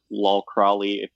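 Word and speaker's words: Lol Crawley: It doesn't have a lot of texture, but Lal Crowley, that's how you say his name, shot it, and I really like his work.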